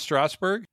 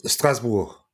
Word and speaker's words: Strasbourg: So is it not pronounced Strasbourg? – No, it's like that, but Strasbourg.